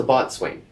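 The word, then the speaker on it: boatswain: Oh, and I found out we had a little disagreement on how to pronounce that. – Mm-hmm. – It's the bosun, as you say. – I'd say bosun. Yeah, I think I say bosun. I don't know that I'm correct. – Or there's the botswain. – Mm. – And they're actually both correct. I think one is the bosun is the newer pronunciation. – Oh. Yeah. – So I think it's an American thing.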